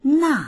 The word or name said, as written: na4